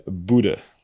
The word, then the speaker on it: Bodø: En je schrijft Bodø met op het einde een O met een streepje erheen. – Ja. – En die spreek je dus uit als een E. Dus denk aan Ødegaard. – Okay. – Dus wat zeg je? Boede.